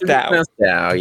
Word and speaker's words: Tao: Yeah, it should be pronounced Dao.